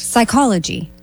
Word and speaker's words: psychology: Psychology. – Correct. The P is silent in psychology.